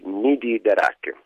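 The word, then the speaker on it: Nidi d'Arac: Come si pronuncia? – Allora, stai parlando dei Nidi d'Arak.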